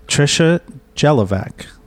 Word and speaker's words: Tricia Jalovec: I have with us a friend of the show, Tricia Jalovec. Did I say that right? – You sure did.